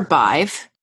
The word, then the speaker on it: Badb: Okay, so do you remember Bive from the first episode? – I do. – Do you remember any details about her? Just her name is weird because it's spelled B-A-D-B.